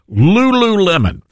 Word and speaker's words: Lululemon: Is it Lululemon? Is that how you correctly pronounce it, Dylan? I've never shopped there. Yeah, that's right. – Lululemon.